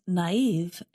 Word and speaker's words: naive: Naive is spelled N-A-I-V-E, and we put two little dots above the letter I to show that we pronounce that vowel separately. And gullible is spelled G-U-L-L-I-B-L-E.